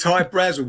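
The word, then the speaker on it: Hy-Brasil: But it's High Brazil because that's how the Irish would pronounce it, because it's an, okay, it's an island that's off the southeast, sorry, southwest coast of Ireland.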